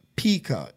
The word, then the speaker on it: pica: 30% of pregnant women crave non-food items, an eating disorder called pica. 30% of pregnant women crave non-food items, an eating disorder called pica.